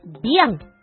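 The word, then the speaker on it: 𰻞: 読み方は、ビアンと読みます。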